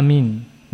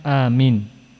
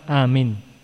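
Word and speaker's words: Amin (آمين): Ucapan yang benar diterangkan Al-Imam Nawawi ada dua lughah. Yang pertama amin bil mad wa takhfifil mim. Amin atau amin. Nah, sudah. Ini yang benar. Yang pertama ini riwayat yang paling kuat. Bil mad wa takhfifil mim. Yang kedua bil qasr wa takhfifil mim. Dengan qasr pendek namun mimnya tidak ditasydid.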